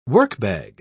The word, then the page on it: workbag: British English